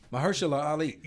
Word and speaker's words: Mahershala Ali: no, but it's Mahershala Ali.